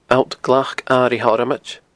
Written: alltglacairighthormoid

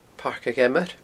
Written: Pairc a' Gheamair

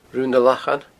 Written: Rubha nan Lachan

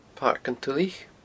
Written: Pairc an Tulaich

